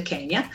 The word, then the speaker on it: Quenya: È una lingua coniata da Tolkien, che è un elfico, l'elfio Kenya, si scrive Quenya ma si legge Kenya,